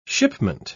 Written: shipment

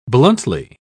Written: bluntly

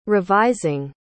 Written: revising

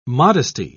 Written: modesty

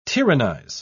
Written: tyrannize

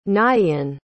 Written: nighean

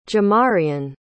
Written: jamareon

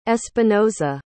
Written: espinoza

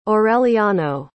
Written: aureliano

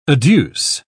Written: adduce

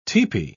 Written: tepee

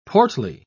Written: portly

